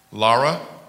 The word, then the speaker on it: Lara: Did I say it right? I did? Because you don't like Laura. Yeah, you better be Lara. I could tell by the way you pronounce it. It's like Lara, because you can see the whole, you was like Lara.